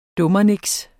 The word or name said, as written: dummernik